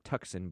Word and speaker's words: Tucson: It looks like Tucson, but it's pronounced Tucson.